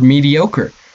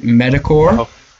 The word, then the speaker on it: mediocre: Today's show is brought to you by Chris's pronunciation of the word mediocre. He pronounces it Medicore, which is not quite the exact pronunciation,